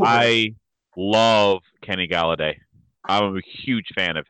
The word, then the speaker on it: Golladay: His name rhymes with holiday. Kenny Golladay, over under 1,199.5 receiving yards.